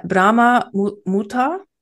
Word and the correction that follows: Brahma Muhurta: Brahma Muhurta.